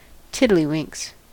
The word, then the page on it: tiddlywinks: American English